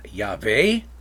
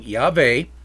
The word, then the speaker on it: Yahweh: And so I pronounce God's name Yahweh. There are other people who pronounce it a different way, but you'll see me probably using Yahweh, what I think is the way you pronounce God's name, rather than Lord.